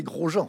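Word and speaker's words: Gros-Jean: au début j'avais dit gros Jean comme devant, mais c'est gros Jean. – Gros Jean comme devant. – Ça s'écrit pareil.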